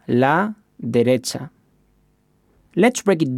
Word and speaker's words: derecha: Finally, we have derecha, which means right. Let's break it down by syllable. De, re, cha. And hear it once again. Derecha.